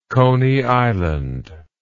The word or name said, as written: Coney Island